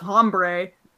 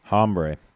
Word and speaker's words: hombre: I don't think it was JR, but somebody said, that Vader is one tough hombre. – Yes. – And I was like, hombre? – Hombre. – You're not, he pronounced the H, like hard H.